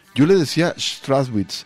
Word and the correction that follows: Strachwitz: pero al parecer es este em, alemán de ascendencia, entonces ahí voy, a intentar decirlo bien, Chris Strachwitz ahora sí lo dije bien, eh, este hombre es un refugiado no de la guerra mundial, sino de la posguerra.